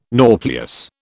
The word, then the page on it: nauplius: British English